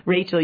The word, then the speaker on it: Rachael: Is Rachel Hutzler. Its first name is spelled R-A-C-H-A-E-L, and then my last name is J-U-Z-E-L-E-R.